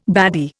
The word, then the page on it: baddy: Indian